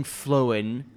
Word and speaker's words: flown: Am I saying that weird? – Flown. There you go. Like a phone. – Imagine at the age of 22, getting. I'm about to say it wrong. Flown. – Yeah, I think flown. Like phone.